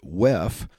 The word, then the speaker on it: WEF: So first, before I get to that, if you don't know what the World Economic Forum is, or WEF for short, or as attendees will pronounce it, WEF, the World Economic Forum was founded by a man by the name of Klaus Schwab in 1971.